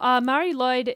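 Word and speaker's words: Mari Lwyd: The festival. Mary Lloyd is M-A-R-I space L-W-Y-D. – Uh-huh. That's why we're giving up on it. – Yeah. Just saying, Mary Lloyd.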